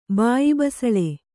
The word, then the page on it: ಬಾಯಿಬಸಳೆ: kannada